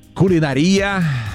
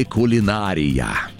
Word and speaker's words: кулинария: Ну, смотрите, на самом деле варианты называют равноправными. Как, кстати, пиццерия и пиццерия. Вот так вот, по старой норме, старая норма, там, до 2000 года ударение было на третье слово. Кулинария. Представляете? Это старая норма. Но сейчас кулинария в некоторых орфоэпических словарях помещается как устаревшая.